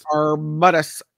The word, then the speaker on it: Arbutus: Arbutus. A-R-B-U-T-U-S. Arbutus.